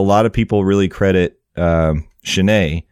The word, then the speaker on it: Chenier: It's spelled C-H-E-N-I-E-R. I actually looked up the pronunciation because I wanted to say it right. I didn't want to embarrass myself. – That's not how I would have pronounced it. So yeah, I'm glad you looked it up.